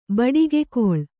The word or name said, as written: ಬಡಿಗೆಗೋಲು